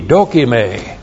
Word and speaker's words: dokime: D-O-K-I-M, long E, dokime.